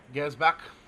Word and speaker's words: Gersbach: It's Antonis, Burns, Cahill, Federici, Gurria, Gersbach, is that how you say his name? Gersbach. – Gersbach.